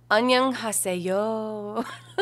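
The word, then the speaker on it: Annyeong haseyo (안녕하세요): So I believe it goes um Annyeong haseyo.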